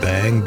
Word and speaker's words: Beyng: We're on section 137 of Contributions to Philosophy of the Event by Martin Heidegger, and the section is called Bang, B-E-Y-N-G, which is his alternative spelling of being, but we pronounce it bang to just differentiate it.